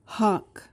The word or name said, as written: hock